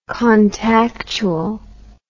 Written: contactual

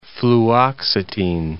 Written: fluoxetine

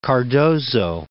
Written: Cardozo

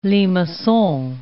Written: limacon

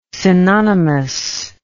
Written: synonymous

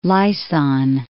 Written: Laysan

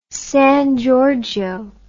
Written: San Giorgio

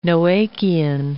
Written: Noachian

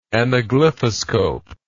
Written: anaglyphoscope

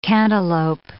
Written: cantaloupe

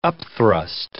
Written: upthrust

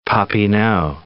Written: Papineau

